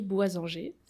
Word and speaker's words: Boisanger: Et c'est là que tu m'as fait découvrir Victoria, alias Atelier Boisanger. C'est comme ça qu'on prononce Oui, Très Bien.